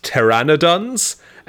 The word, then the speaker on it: pteranodons: At one point during the trip, Tanya became lost, and Carol found her in a cave being attacked by gigantic pternodons. – This took a twist. What are pternodons? – I think it's actually pronounced pteranodons.